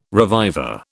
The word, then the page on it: reviver: British English